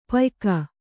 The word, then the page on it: ಪಯ್ಕ: payka